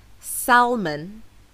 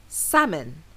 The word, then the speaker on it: salmon: However, the correct pronunciation does not include the L sound. So it's not salmon, it's salmon, as in, the salmon in the salad is so fresh.